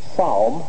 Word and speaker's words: psalm: I believe the P is silent. – Thank you. I thought that was psalm, like palm, the middle of your hand.